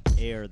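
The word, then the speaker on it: Eyre: Air, that's E-Y-R-E, but it's pronounced like A-I-R.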